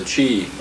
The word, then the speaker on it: qi: I guess that's actually should be a Q, or it's pronounced C-H-I. The qi,